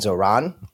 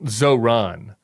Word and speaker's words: Zohran: And why do you say it Zohran? I think it's Zohran. Is it? Do you put the emphasis on? I think you're putting the emphasis on the wrong syllable, I think.